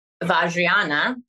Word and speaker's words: Vajrayana: Vajrayana, Tantra, yeah.